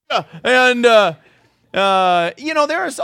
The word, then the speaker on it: kombucha: How many enjoy a kombucha? Or what, am I saying it right? Yeah. If you're, yeah, there you go.